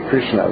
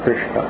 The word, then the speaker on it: Krishna: But it's, Yes, it's like R-U, Krishna. – Mm. – We say Krishna.